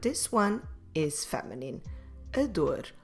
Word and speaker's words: dor: So first of all, the word for ache or pain in Portuguese is the word dor. A dor. Como se escreve dor? D-O-R.